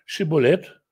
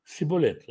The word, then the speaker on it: шиболет: То есть это такая вот такой вот дефект речи был у ефраимлян, И они говорили ему, скажи «шиболет», и он говорил «сиболет». Это стало термином в лингвистике, как какое-то слово, произношение которого определяет принадлежность, определяет как бы культурную принадлежность человека. Потому что он не мог сказать правильно слово «шиболет».